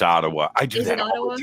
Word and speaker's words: Ottawa: But it is Ottawa. – Ottawa.